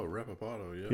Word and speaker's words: Reposado: Is it the Reposado? – Is that how you pronounce that? – R-E-P-S-A-D-O. – Oh, Reposado, yeah.